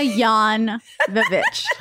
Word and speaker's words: Jovovich: Like Jovovich, but Jovovich. It's pronounced yeah, like yawn. – Yeah. Yawn. Milla Yawn Vovich. That's what people remember you. – It's like silent J.